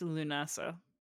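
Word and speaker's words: Lúnasa: So it's pronounced Lunasa. Because it's a schwa, so you gotta I can't speak phonetic.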